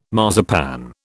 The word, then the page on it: marzipan: British English